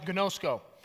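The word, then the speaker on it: gnosko (γινώσκω): And we talked about this because we said, listen, in Scripture, when it talks about knowing God, it uses this Greek word called that's pronounced gnosko.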